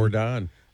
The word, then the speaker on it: Yordan: Yordan. – I hope we don't trade him. – No relation to Michael Yordan. – No, but they do call him Air Yordan. – I love the soft J.